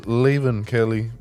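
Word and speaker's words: Leven Kale: Det udtales Levin Kelly, men det staves, eller hans fornavn staves L-E-V-E-N. Og så K-A-L-E.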